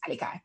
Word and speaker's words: Alakai: But the only difference is that I said the only way it's Alakai Harley is you got to make sure as well the accent is on the last I, because that way if you put it on YouTube, it's Alakai.